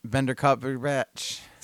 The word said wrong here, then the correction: Benedict Cumberbatch: I don't know how to say his name. – Benedict Cumberbatch. Yeah, finish that whiskey. – Benedict Cumberbatch.